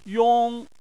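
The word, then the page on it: yong: tone 1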